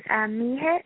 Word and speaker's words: Mihich: Yeah, it is Mihich. It's spelled M as in Mary, I-H, and then I-C-H. – M-I-H-I-C-H? – Yes.